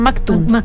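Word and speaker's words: Maktoum: Ella está casada con la sexta esposa del emir Mohammed bin Rachid Al Naptun. Lo dije bien, Maktun. Exactamente.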